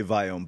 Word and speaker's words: Viome: So how would you pronounce it? – I would say Viome, but don't feel bad. My dad would probably say it the same way you do.